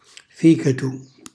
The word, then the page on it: ficatu: calabrese